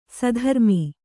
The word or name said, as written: ಸುಧರ್ಮೆ